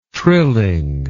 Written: trilling

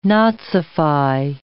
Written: nazify

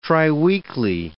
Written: triweekly

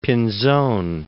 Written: Pinzon

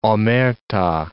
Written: omerta

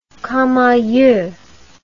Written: camaieu

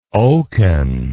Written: oken

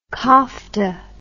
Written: kofta

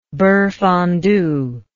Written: beurre fondu

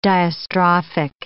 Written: diastrophic

